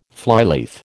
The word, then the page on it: flyleaf: American English